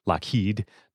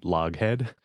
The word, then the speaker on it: Lockheed: Yes, but it was pronounced Lockheed because it is Scottish, like Loch, like Loch Ness, Lockheed, not Loghead.